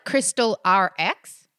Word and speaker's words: Crystal RX: And you pronounce it Crystal RX? That's right, just like crystal prescriptions, like the RX.